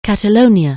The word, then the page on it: Catalonia: anglais